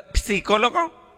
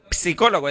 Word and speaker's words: psicólogo: Digo, mire, primero que todo no se dice psicólogo, se dice psicólogo. Digo, no, el doctor psicólogo, es que allá afuera dice psicólogo. Digo, no, es que la P no se pronuncia, pero de todas maneras el doctor no está.